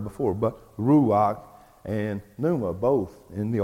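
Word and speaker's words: ruach (רוח): The first is in the Old Testament, and it is the Hebrew word ruach. Ruach, R-U-A-H, some people spell it R-U-A-C-H, ruach, Hebrew gets translated to the English word spirit.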